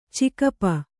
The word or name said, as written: ಚಿಗಪ್ಪ